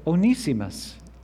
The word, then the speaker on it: Onesimus: Now, earlier this year, I took an online class with a British professor, and he said Onesimus, and I think that gives a little more dignity to the name. So if I say it again, I'm going with the British pronunciation.